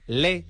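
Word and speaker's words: Lé: A las 7:30 en la librería Le, con acento en la E, Le.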